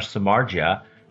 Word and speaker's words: Samardzija: Jeff Samardzija, see, I do know how to pronounce it out there, folks.